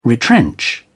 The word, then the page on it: retrench: British English